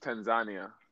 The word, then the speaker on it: Tanzania: And, like, so when I was in the mainland, Tanzania, well, it's pronounced Tanzania. That's okay. – They corrected me and I was there. That's how you really pronounce it.